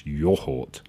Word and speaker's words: yoghurt: Um, we all know, of course, that the correct pronunciation of the word is yoghurt, not yogurt. It's yohort.